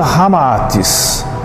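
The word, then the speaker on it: Ramatís: Unindo-se Rama e Atis, ou seja, Zita ao inverso, então resulta Ramaatis, como realmente se pronuncia em indo-chinês.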